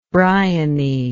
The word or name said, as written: briony